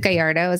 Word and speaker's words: Gallardo: Emily, is it Gallardo? Is that how you say your name? – That's the proper way to say it, but I can't even say it like that.